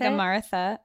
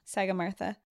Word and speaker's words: Sagarmatha: Is that how you say it? – Sagarmatha. It's Mount Everest. – Mount Everest, yeah. Sagarmatha.